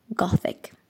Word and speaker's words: Gothic: qu'il faudrait d'ailleurs bien prononcer Gothic.